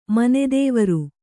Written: ಮನೆದೇವರು

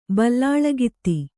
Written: ಬಲ್ಲಾಳಗಿತ್ತಿ

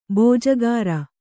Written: ಬಿಜಾಗರಿ

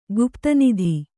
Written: ಗುಪ್ತನಿಧಿ

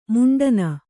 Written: ಮಂಡಣ

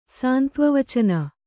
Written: ಸಾಂತ್ವವಚನ

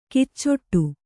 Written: ಕಿಚ್ಚೊಟ್ಟು

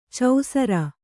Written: ಚೌಸರ

